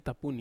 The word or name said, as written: tapuni